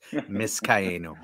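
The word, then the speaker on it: Vizcaino: I think you're pronouncing it wrong. Must be that English accent. It's Viscaino. Viscaino.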